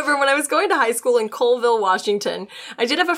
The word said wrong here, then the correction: Colville: Sorry, it just told me how it's pronounced. Pronounced Calville. Sorry, I'll go back. However, when going to high school in Calville, Washington, I did have a friend who saw cryptid once.